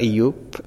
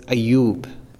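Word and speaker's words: Eyüp: It's spelled Ayyub, but it's not Ayyub, it's Ayyub.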